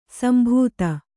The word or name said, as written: ಸಂಬಾಧ